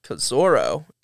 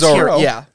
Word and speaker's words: Zorro: My favorite shot is Kazoro, or Zorro, whatever his name is. – It's Zorro.